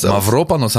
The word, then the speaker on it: Mavropanos: Spricht man tatsächlich Mavropanos? – Mavropanos haben ja meine griechischen Freunde.